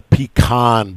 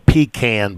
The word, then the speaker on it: Pecan: December 12, 1970, the Pecan Bowl, or Pecan Bowl, depending on where you're from, how you want to pronounce that.